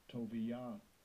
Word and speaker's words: Tobijah: Strong's H2900, Tobijah, Tobijah.